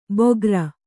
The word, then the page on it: ಬೋಗರ: bōgara